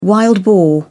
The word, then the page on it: wild boar: British English